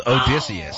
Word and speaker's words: Odysseus: Listen, you mispronounced Jahmyr Gibbs' name, not Jahmyr Gibbs, Jahmyr, but you nailed Odysseus.